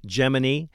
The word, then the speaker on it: Gemini: You've got Mercury, Gemini, which is the correct pronunciation, by the way, for everybody that thinks I'm saying it wrong.